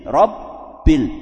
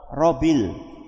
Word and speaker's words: rabbil: Tasydidnya juga demikian. Tasydid ini harus dibaca. Kayak tadi alhamdulillahi rabbil, rabbil. Enggak boleh jenengan bacanya alhamdulillahi rabbil. Napa? Robil. Berarti tasdidnya tidak dibaca.